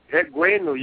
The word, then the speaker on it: Egwuenu: Well, you have to let me know, did I pronounce your last name correctly? – Okay, pronounce it. Start with here. Pronounce it. – Egwuenu? – Yeah, try it. Egwuenu. Yeah, it's the same. – Egwuenu. – You know, it's, yeah, Egwuenu, yeah. That's the right pronunciation.